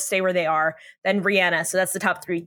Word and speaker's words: Rihanna: Rihanna is a billionaire. She has reached billionaire status thanks to Fenty Beauty. – Wait, I think it's pronounced Rihanna.